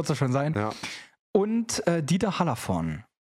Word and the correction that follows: Hallervorden: Genau. Hallervorden, Dieter Hallervorden. Ich, ich nuschel das einfach immer weg, damit man nicht weiß, ob es richtig ausgesprochen ist.